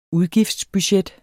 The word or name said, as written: udgiftsbudget